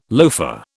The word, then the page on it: loafer: British English